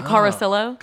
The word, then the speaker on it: Carosillo: Okay, because I've been doing the two L's must be pronounced the Spanish way. – Yeah, I think it's just a bastardized Italian last name. Oh. Carosillo.